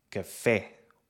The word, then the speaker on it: café: Em certas palavras, a sílaba tónica pode ser a antepenúltima, como em sílaba, ou a última, como em café ou aqui.